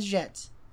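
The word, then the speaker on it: Jatte: Is it Jette? – Jette. – Jette? – Yeah. – La Grande Jette.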